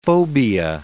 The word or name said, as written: phobia